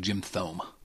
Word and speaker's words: Jim Thome: Well, that's a lot of strikeouts, but I think you mispronounced his name. I think it's actually Jim Thome.